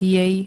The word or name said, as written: jej